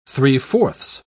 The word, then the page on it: three-fourths: British English